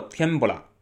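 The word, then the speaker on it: 天ぷら: 天妇罗，嗯，读作天不辣啊，就是蜡笔小新总吃总说那我要吃天不辣，天不辣，天不辣，它就这么读音啊。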